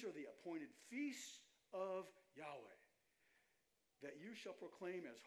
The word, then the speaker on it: Yahweh: and either pronounce it Yahweh or Yahuwah.